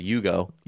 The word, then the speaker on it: Hugo: Sorry, Hugo Gonzalez. There's no H. You don't pronounce it with an H.